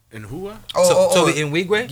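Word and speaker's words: Tobe Nwigwe: Speaking of Houston, yo, I've been listening to Toby in Wigwe. – Toby Nwigwe? – Yeah, I ain't know how to pronounce it.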